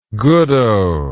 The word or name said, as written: goodoh